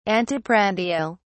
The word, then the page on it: anteprandial: British English